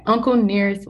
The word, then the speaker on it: Uncle Nearest: With that, everyone go out and buy a bottle of Uncle Nearest. Did I say that right? Yeah. Okay. Uncle Nearest whiskey.